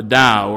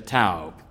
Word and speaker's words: Tao: They talked about the Tao, or if you mispronounce it the way it's usually mispronounced, you would say the Tao.